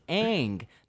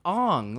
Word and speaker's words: Aang: His name is Aang, not Ong.